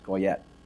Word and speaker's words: Goyot: This year, the board has selected Sandra Goyet, G-O-Y-O-T, pronounced Goyet, for her tireless work with Golden Harvesters, a nonprofit providing meals and serving low-income families in our community.